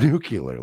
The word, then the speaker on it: nuclear: It's nuclear. It's pronounced nuclear, Lisa.